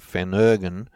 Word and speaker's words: Phenergan: It seems they rely on something called Phenergan, P-H-E-N-E-R-G-A-N, Phenergan, and they even don't pronounce that the same way as we do. They call it Phenergan, Phenergan.